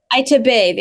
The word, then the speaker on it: Itabe: Itabe, the emphasis on the last syllable.